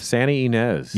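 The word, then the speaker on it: Santa Ynez: Santa Inez.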